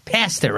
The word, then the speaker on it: pasta: That is how they say it in England. Pasta.